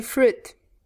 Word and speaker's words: fruit: In English, we say fruit. Fruit. – Exacto. No se pronuncia la i.